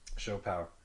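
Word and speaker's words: siopao: Siapao 888. So Shopao. – Shopao. Is it Shopao? – Yeah, it's a Tagalog word.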